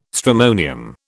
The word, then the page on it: stramonium: British English